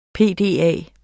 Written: PDA

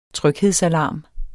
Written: tryghedsalarm

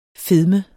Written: fedme

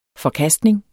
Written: forkastning

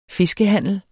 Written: fiskehandler

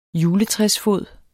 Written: juletræsfod

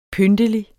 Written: pyntelig

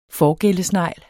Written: Prosobranchia

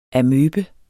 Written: Sarcodina

